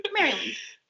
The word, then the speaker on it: Maryland: Maryland.